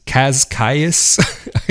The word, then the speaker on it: Cascais: half an hour away in Cascais. C-A-S-C-A-I-S, Cascais, I guess would be the way you'd pronounce it in English, but Cascais.